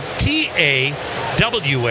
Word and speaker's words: Tawa: You spell his last name, T-A, WA. It is pronounced Tawa.